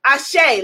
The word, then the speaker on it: Ase: Ashe, let's affirm it. Type into the comments, Ashe. A-S-E is the easiest way to spell it.